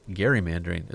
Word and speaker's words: gerrymandering: Or, as I learned officially, it's gerrymandering is the correct way to say it, because it was named after Governor Gary.